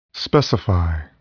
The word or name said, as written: specify